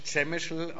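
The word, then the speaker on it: Przemyśl: Das wird auf Deutsch Chemischel ausgesprochen. Da müsst ihr hier den Nathan fragen, warum die Polen anders schreiben, als sie reden.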